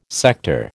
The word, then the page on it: sector: American English